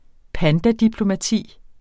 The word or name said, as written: pandadiplomati